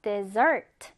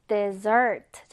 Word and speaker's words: dessert: 자, 중요한 건 강세가 요 뒤에 붙어요. 발음교도 보면 강세 표시가 뒤에 돼 있죠. dessert. 후식, 디저트란 뜻이고요. 지금 아래 보면 desert, 사막과 단어 철자가 비슷해요라고 하는데 강세 차이도 잘 알아두셔야 돼요.